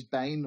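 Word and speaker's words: bain (בן): Now, in Hebrew, the word is bain, or ben is the way it's written, but bain is pronunciation.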